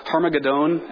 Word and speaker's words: Harmagedon: Verse 16, they assembled them at the place that in Hebrew is called Harmagedon. And I know I'm pronouncing that weird, but that is a pronunciation in like Hebrew and Greek.